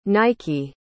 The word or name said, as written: naiki